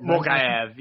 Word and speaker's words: Mokaev: So the fighter in question that John wants to know about, is what, Mohamed Mokaev? Is that how you say it? Mokaev, yeah.